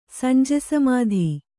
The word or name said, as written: ಸಂಜೆಸಮಾಧಿ